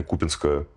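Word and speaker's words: Купянска: Да, ты прав в той части, что все наступательные действия российской армии в районе Купинска, такое ударение, по крайней мере, используют в Украине, в районе Купинска имеют своей целью будущую гипотетическую операцию по захвату северной части Донбасса, то есть того же самого Славянска и Краматорска.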